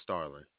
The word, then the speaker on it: Starling: My name is Starling, S-T-A-R-L-I. N-G.